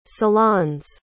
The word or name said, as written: salon's